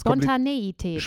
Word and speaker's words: Spontaneität: Spontanität. – Nein, das Zweite ist Spontaneität. – Spontaneität. – Ja. Und zwar auch so mit der Betonung, Spontaneität.